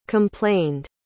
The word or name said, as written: complained